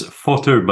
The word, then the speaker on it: Fauteux: It's Fauteux, but that was close enough.